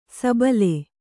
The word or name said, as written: ಸಬ್ಬಲು